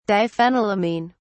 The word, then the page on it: diphenylamine: British English